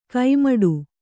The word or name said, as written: ಕೈಮಡು